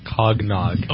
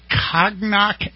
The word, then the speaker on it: cognac: He said, today is Cognac and Cheese Day. And I said, Cognac. I said, how do you spell that? C-O-G-N-A-C. You mean cognac? – Well, he's 18.